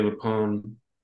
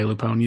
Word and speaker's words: LuPone: I mean, I definitely would like to see, I'm glad you mentioned Patti LuPone. Is that how you say her name? – I thought it's Patti LuPone, yeah.